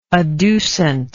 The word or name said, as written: adducent